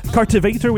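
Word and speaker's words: Cartivator: Cartvator, oui. Il faut prononcer apparemment le point d'exclamation qu'il y a entre Cart et Vator comme un i.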